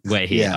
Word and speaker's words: Guehi: Mark Gui. That's not how you pronounce it. It's a Crystal Palace. Guehi.